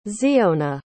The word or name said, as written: tziyona